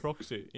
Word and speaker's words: proxy: Så här uttalas det på amerikanska. Ja. Proxy. – Ja, Proxy. Inte Proxy som jag hade.